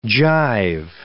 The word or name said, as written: gyve